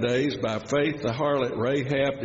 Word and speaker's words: Rahab: How do you say that? Rahab. Got it.